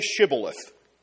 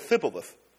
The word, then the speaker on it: Shibboleth: And so they say Shibboleth. Good Jewish word. Say Shibboleth. And the foreigners knew all the letters, but they couldn't pronounce it. And so they said Thibboleth.